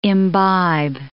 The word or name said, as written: imbibe